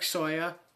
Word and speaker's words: Sawyer: So if you type in Derek Sawyer, don't forget the C in my name because you will not find me.